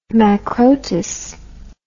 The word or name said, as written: macrotous